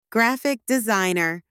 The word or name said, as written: graphic designer